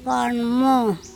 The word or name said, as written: kOnmo